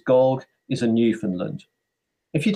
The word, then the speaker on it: Gog: Oh, Gog rhymes with dog.